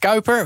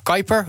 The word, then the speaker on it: Kuiper: Amazon wil dat trouwens gaan doen met het altijd Nederlands interessant klinkende Project Kuiper, of Kuiper, hoe je het ook op zijn Amerikaans wordt uitspreken.